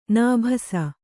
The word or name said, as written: ನಭಸ